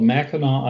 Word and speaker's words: Mackinac: And just off to that a little bit is a place called Mackinac Island. It's spelled, it's a French spelling, M-A-C-K-I-N-A-C, Mackinac Island.